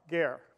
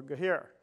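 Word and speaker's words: ger (גר): The word for stranger or alien in Hebrew is ger, G-E-R. It's pronounced G-H-A-I-R, ger, or gehir, no, ger.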